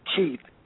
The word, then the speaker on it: Keith: My last name is spelled B-E-A-U-C-H-A. M as in Mark and P as in Paul. Keith, K-E-I-T-H, first name.